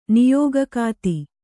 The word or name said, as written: ನಿಯೋಗಕಾತಿ